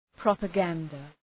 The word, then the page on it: propaganda: ,prɒpə’gændə